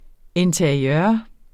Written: interiør

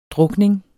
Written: drukning

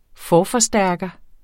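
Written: FORFORSTÆRKER

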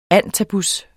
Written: antabus®

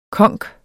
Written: konk

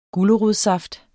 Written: gulerodssaft

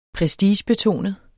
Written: prestigebetonet

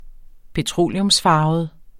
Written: petroleumsfarvet

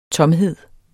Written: tomhed

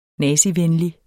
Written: nazivenlig

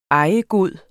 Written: ejegod

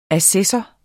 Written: ASSESSOREN

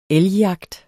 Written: elgjagt

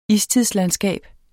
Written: istidslandskab

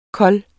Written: kold

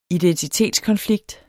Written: identitetskonflikt

